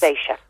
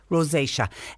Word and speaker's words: rosacea: Pat has been diagnosed with, is it pronounced Rosa? On my face, red patches. – Rosacea. – Rosacea.